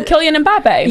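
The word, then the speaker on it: Kylian Mbappé: Is that how you would? – Oh, Kylian Mbappe.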